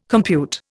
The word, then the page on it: compute: Indian